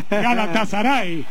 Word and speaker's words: Galatasaray: ¿En qué equipo de Turquía juega vos, que te gusta pronunciar bien a los turcos? – Galatasaray. – Bueno. Así, bueno, así lo decimos nosotros también. Galatasaray.